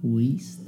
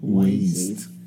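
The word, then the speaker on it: Wiest: Weist. – Weist.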